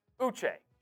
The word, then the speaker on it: Uche: Uche. – Josh Uche.